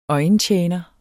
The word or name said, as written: øjentjener